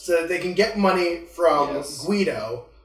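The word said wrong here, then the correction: Watto: I don't think that's how he pronounces his name. – Just realized something. a racial slur for his name. It basically is. – Anywho, his name's, no, it's Guado, right? – Yeah, Guado.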